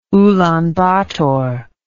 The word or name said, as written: ulan bator